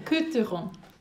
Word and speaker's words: Côtes du Rhône: I don't know if I've pronounced that correctly. Côte du Rhône. – Côte du There you go.